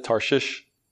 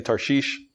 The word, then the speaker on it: Tarshish: Some people pronounce it Tarshish. I'm just going to say Tarshish.